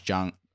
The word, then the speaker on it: Jung: Other notable rookies to begin the 2023 campaign include Gunnar Henderson, Brett Batty, Jake Outman, Josh Jung, I actually said that right, and Hunter Brown.